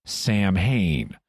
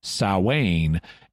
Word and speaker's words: Samhain: So, based on its spelling, it's not surprising that Berkowitz and his group would think it was pronounced Samhain. Incidentally, in more recent interviews, Berkowitz uses the correct pronunciation, Samhain, and explains that they pronounced it Samhain back then.